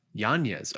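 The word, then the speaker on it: Yáñez: It's supposed to be Yanez. Yanez.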